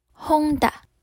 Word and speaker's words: junta: 那这一个字 Junta，它的字源是来自西班牙文，西班牙文的念法叫做 Honda。英式发音叫做 Junta，Junta。那崔呢都是教美式发音，所以我们会念 Junta，Junta。